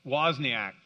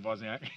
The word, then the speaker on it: Wozniak: He was one of the, there were three founders, Steve Jobs, Steve Wozniacki, I probably butchered that. I'm sorry? Wozniak, thank you. Yeah, sorry, Steve Wozniak.